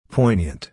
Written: poignant

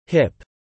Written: hip

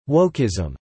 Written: wokeism

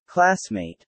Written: classmate

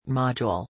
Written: module

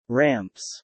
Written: ramps

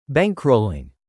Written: bankrolling

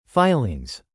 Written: filings

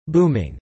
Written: booming

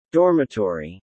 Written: dormitory

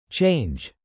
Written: change

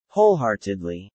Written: wholeheartedly

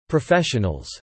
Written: professionals

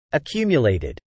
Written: accumulated

